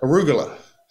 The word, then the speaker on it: arugula: So mostly I'd have a big salad with a mix of greens, but also put argillus in it. Argillus, is that how you pronounce it? – Arugula. – Arugula.